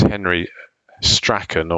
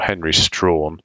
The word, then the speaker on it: Strachan: The Jamaican neuritis was the first person to describe it was a chap called, you can pronounce it two ways, Henry Strachan or Henry Strawn.